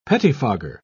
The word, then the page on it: pettifogger: British English